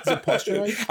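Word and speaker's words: posterize: Is it posturize? – I think it's posturize because like when they do it, it's like they're turning you into a poster.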